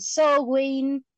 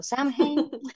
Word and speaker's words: Samhain: Igual también muchas personas pronuncian Sowin, eh, yo pronuncio Samhain.